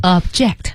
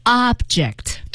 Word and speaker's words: object: Object，这是做动词的时候，当然大家一定也知道了，它还有一个读音啊，叫做 object。 – 对，那您仔细听哈，做动词的时候是 object，而做名词的时候是 object。也就是说前面的重音往前提，而且后面那个 J-E-C-T 那个 E 要读成一个小 E 的音。